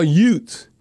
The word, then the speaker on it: Utes: The one thing about the Saints organization, we love Utah Utes. You know, I'm not pronouncing the TH because there's no TH.